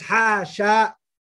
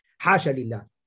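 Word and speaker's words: حاش لله: Abu Amr, he recited as حَاشَ with the alif after the sheen, in terms of pronunciation, lakin fi halati al-wasli, of course, the time when it's been connected, okay? And the remaining other scholars, they read it as حاشا لله.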